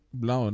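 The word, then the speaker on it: Blough: David Blau, wie wird er ausgesprochen? – Blau, ne? Blau.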